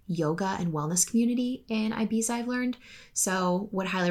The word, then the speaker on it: Ibiza: And on that note, I'm also going to throw in Ibiza, or if you want to be douchey, Ibiza. And it's only douchey if you say that and you're American, by the way, because that's actually how it's pronounced.